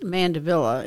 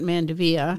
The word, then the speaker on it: mandevilla: But first of all, it is pronounced mandevilla. It's not mandevilla. – Mandevilla. – And the reason is because it was named after Henry Mandeville, and he was a British gardener.